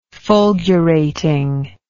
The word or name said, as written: fulgurating